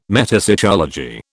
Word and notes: metapsychology: British English

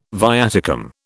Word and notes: viaticum: British English

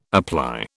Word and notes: apply: British English

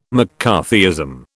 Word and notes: McCarthyism: British English

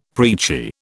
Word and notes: preachy: British English

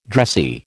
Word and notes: dressy: American English

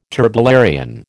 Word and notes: turbellarian: American English

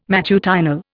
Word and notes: matutinal: Indian

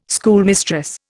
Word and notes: schoolmistress: Indian

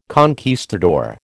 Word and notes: conquistador: American English

